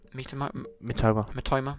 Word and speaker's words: Mitoma: Mitoma,